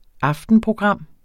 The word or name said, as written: aftenprogram